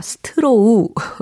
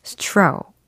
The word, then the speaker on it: straw: 스트로우. 네, 하지만 정확한 영어 발음으로는 straw. 이렇게 하셔야 되겠죠. S-T-R-A-W.